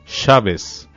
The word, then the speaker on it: Shabbos: Shabbos. – Shabbat, sábado en hebreo, en pronunciación yiddish.